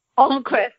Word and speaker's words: Almquist: Almquist.